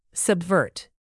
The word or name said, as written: subvert